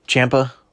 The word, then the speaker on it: Ciampa: There's a guy named Tommy Ciampa. I think I'm saying that right. It's not spelled Ciampa, but like C-H, but it's C-I.